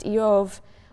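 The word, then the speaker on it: Eov (איוב): The name Job, which in Hebrew is pronounced Eov, is sort of bivalent in meaning.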